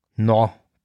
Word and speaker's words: Knorr: Ähm, da folgte auf Rutherford ein neuer Präsident der Wachturmgesellschaft, ein Mann namens Nathan Homer Knorr. Knorr schreibt man ihn, ich glaube, man spricht das K nicht aus im Englischen, K-N-O-R-R.